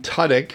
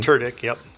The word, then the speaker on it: Tudyk: Alan Tudyk, which is the correct way to pronounce the name. – Tudyk, yep.